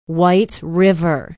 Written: white river

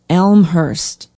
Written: elmhurst

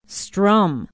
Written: strum

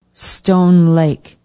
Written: stone lake

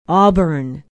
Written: auburn

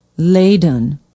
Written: Leyden

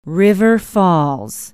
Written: River Falls

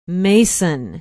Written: mason

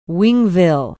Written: wingville

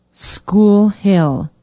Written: School Hill

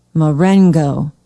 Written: marengo

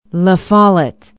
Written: la follette

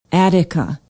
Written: attica

